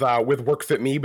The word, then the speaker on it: WorkFitME: Now, is it WorkFitME or is it WorkFitMaine? How do you pronounce that? – I usually go with WorkFitME, but it's kind of a play on WorkFitME, WorkFitMaine.